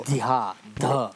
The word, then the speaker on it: Vidya: Ona to píše V-I-D-Y-A. – But we pronounce it V-I-D-H-Y-A. – Ale my to Vidya, dha.